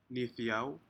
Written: nidou

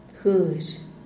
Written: guexh

